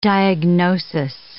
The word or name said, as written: diagnosis